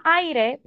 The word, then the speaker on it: Aire: Nene se llama aire, pero se pronuncia ah, air. Exacto, air.